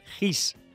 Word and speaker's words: hygge: Dicen que la clave en otoño es practicar el GIS. Ojo, este concepto se escribe HYGGE y se basa en una costumbre nórdica de buscar el confort en lo pequeño.